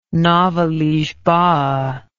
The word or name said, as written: nova lisboa